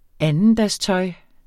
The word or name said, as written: andendagstøj